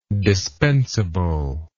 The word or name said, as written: dispensableness